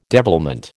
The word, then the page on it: devilment: American English